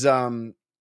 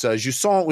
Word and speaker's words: Jusant: It is Jusant. So it looks like Jusant, but it's Jusant, which is a French word that means rising tide, I think.